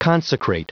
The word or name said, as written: consecrate